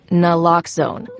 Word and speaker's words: naloxone: For the record, it's Naloxone. Nuh, like, is Naloxone addictive? Nuh. Lox, like, Naloxone keeps humans safe, like, locks keep bikes safe. And own, like, Naloxone can save lives in our very own communities.